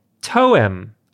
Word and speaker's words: Toem: And the game that I'm bringing is called Toem, I think is the way it's pronounced. Like poem, but with a T.